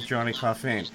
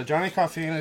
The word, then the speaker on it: Johnny Coffeen: And the latest in that series is the interview that I'm going to be doing right now with Johnny Coffeen. Johnny, I hope I'm pronouncing your last name properly, am I? – Yes, Johnny Coffeen is correct. – Okay, all right. So Johnny Coffeen is here.